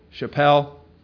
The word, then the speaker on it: shaphel: The second word is, I'll spell it as S-H-A-P-H-E-L, Chappelle.